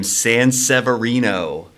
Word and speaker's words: Sanseverino: First and foremost, last name Sanseverino. – Okay. – It sounds exactly the way it's spelled.